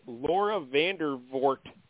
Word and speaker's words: Laura Vandervoort: And now the third star here, this lady plays Frankie, Laura Vandervoort. Am I saying that properly? – Yeah. I think so.